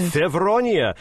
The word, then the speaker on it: Fevronia: Fevronia. Did I pronounce that correctly? – Yeah, right. – Fevronia, which falls on July 8th.